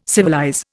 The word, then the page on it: civilize: Indian